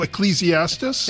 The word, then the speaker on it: Ecclesiastes: I'm going to pronounce this moron, Ecclesiastes? – Oh, Ecclesiastes? – Thank you.